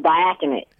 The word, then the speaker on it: diaconate: He was ordained to the diaconate. Could you? How do you say that? – It's really early. I'm just drinking my first cup of coffee. Diaconate. – Diaconate.